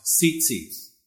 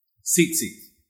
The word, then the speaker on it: tzitzit: The Hebrew word is spelled S-I-S-I-T, and it's pronounced tzitzit, tzitzit.